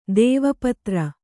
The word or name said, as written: ದೇವಪತ್ರ